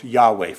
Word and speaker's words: Yahweh: Let's just say it's pronounced Yahweh for the purpose of this sermon.